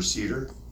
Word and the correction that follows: seder: and uh, did that or seder, I think is how you say it.